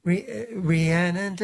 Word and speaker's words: Rhiannon: Rhiannon, so it needs to be Rhiannon, three syllables.